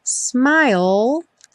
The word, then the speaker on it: smile: smile sem pronunciar o E final. Essa palavra vai aparecer muitas vezes. Lembra que a gente termina o som dela com o L lá no céu da boca.